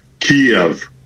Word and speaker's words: Kyiv: it is not as easy as simply saying Kyiv. It's not a single syllable city. It is Kyiv.